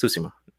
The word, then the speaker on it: Tsushima: So clearly I was pronouncing it in past episodes. It's Tsushima.